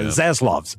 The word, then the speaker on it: Zaslav: Laszlav, I think is how you say his last name. – The new guy. – Zaslav. Z-A-S-L-A-V.